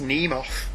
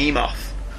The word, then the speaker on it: Nemoth: The actual spelling of it, it's Nemoth. – Is it? – Yeah. The spelling is MN. The M is silent. It's like mnemonic. – Alright, okay. – So it's Nemoth.